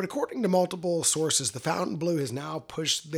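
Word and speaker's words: Fontainebleau: The official pronunciation is Fountain Blue. Good old American Fountain Blue, not Fontaine Bleu or whatever we, you know.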